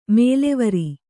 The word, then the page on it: ಮೇಲೆವರ್: Kannada